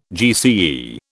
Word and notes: GCE: British English

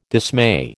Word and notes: dismay: American English

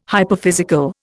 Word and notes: hyperphysical: Indian

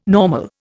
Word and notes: normal: Indian

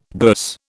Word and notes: bourse: British English

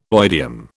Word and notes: oidium: British English

